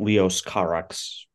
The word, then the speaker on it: Leos Carax: I absolutely don't know how this is pronounced, but Leos Carax.